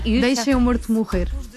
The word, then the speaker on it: Deixa-me Morto Morrer: Deixa-me Morto Morrer. We eat the vowels, so it's very hard.